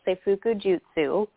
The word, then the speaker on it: Seifuku Jutsu: So it is called, and I probably don't pronounce it perfectly either, not speaking Japanese myself, but it is called Seifuku Jutsu.